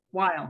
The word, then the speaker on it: Weil: Is it pronounced Weil Chair or Weil? Weil. Weil. Okay.